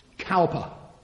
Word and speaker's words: Cowper: Cowper.